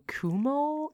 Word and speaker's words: Cuomo: I saw the governor of New York, Andrew Cuomo.